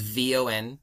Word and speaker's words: von: The first part is just V O N von, bon.